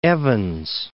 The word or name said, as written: Evans, Mount